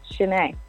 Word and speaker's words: Chenae: Yeah, Chenae.